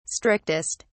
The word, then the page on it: strictest: British English